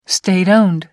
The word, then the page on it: state owned: American English